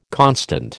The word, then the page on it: constant: American English